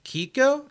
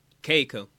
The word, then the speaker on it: Keiko: And it was written by Ryota Yamaguchi and how do you the woman who is always on this list, Kiko? Is it Kiko? – Or what are the letters? – K-E-I-K-O. – Keiko. – Keiko Nobumoto. – In Japanese, you pronounce every vowel.